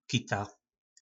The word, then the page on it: kita: po našimu